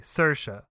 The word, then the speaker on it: Saoirse: Wikipedia says Saoirse. – Saoirse. – S-U-R-S-H-E. That's the pronunciation key. Saoirse. – Saoirse. – Okay. – Okay. Well, now we know.